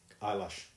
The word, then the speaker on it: Eilish: Eyelash, it's pronounced. – Oh, is it eyelash? – I don't know. – It's E-L-L-I-S-H.